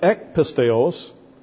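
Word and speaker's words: ek pisteos (ἐκ πίστεως): But this expression ek pisteos, transliterated E-K, P-I-S-T-E-O-S, long O, means practically the same thing as diapisteos.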